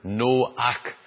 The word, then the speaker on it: Noach: It's the same, and Noah is just a transliteration of a Hebrew word, Noach. It would be pronounced in Hebrew, and it means comfort.